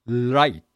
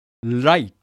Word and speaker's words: light: L の方が Light、 R が Right。 2 つ並べて発音してみましょうね。 Light、 Right、 Light、 Right。